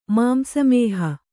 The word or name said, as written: ಮಾಂಸಮೇಹ